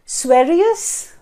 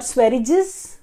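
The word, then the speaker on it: Sveriges: It is economics, and the way we pronounce it is that as Sverius primarily and not Sverriges.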